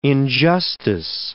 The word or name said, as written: injustice